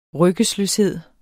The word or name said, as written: ryggesløshed